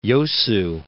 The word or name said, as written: Yosu